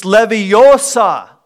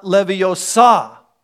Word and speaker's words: Leviosa: It's Leviosa, not Leviosa.